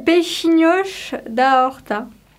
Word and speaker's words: peixinhos da horta: Et ce mot que tu as bien prononcé, que moi je vais sûrement mal prononcer, c'est peixinhos da horta, c'est ça? À peu près? – C'est peixinhos da horta.